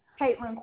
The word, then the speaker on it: Kaitlyn: Caitlin Quarles. – And could you spell that for me? – K-A-I-T-L-Y-N. Are you ready for the last name? – Yes, ma'am. – Q-U-A-R-L-E-S.